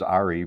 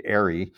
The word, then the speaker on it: Ari: It, like, clearly, even though I told you it's Ari, but you would say Ari, was very frustrating.